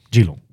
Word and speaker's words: Geelong: Spreek je het zo uit? – Geelong. Okay. Niet Geenslong of zo? – Nee, oké. – Daar lijkt het misschien een beetje op.